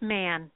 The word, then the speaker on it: Mahon: It's pronounced Man. – Man. Okay, so it's spelled M-A-H-O-N, everyone, and it's Man.